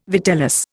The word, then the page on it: vitellus: Indian